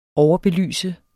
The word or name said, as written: overbelyse